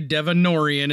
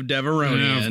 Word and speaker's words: Devaronian: There are committed nerdlings out there who will still say Devonorian instead of Devaronian.